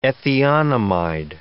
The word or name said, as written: ethionamide